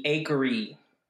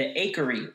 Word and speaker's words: Acheri: Today, we're talking about the Akari. – The what? – The Akari. It's spelled A-C-H-E-R-I.